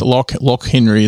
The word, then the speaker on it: Loch Henry: The second we watched is called, I don't know if I'm saying this right, I think it's L-O-C-H. It's like the Loch Ness Monster, uh-huh. but it's Loch Henry is how they said it.